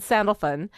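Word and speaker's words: Sandalphon: right, with Gabriel and Sandalfun. – Yes. – Sandalfun. The way they pronounced it sounded like Sandalfoot, but the way it's spelled is Sandalfun. Fawn, so I don't know.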